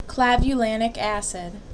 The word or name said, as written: Clavulanic Acid